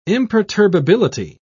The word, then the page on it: imperturbability: British English